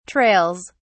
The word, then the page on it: trails: British English